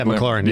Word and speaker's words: McLaurin: Yeah, McLaurin.